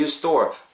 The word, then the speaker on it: store: Do you store 발음하실 때 do you store, S T O R E 요놈만 발음하실 때 store, store 이렇게 발음하시기보다는 store, store, store 약간 er 사운드 들어가죠. i 사운드 때문이에요. store 이걸좀 더 주목하셔서 집중하셔서 where do you store?